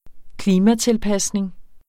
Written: klimatilpasning

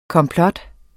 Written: komplot

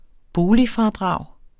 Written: boligfradrag

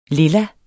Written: lilla

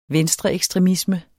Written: venstreekstremisme

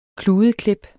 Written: kludeklip

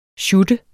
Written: sjutte

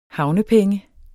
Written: havnepenge

